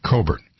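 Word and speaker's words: Cockburn: Alex's last name is spelled C-O-C-K-B-U-R-N. C-O-C-K-B-U-R-N. But it's pronounced, fortunately for him, Coburn.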